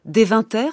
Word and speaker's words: Deventer: Et notamment, en Hollande, il y a une célèbre école des frères de la vie commune à Deventer, c'est comme ça qu'on prononce.